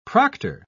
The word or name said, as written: proctor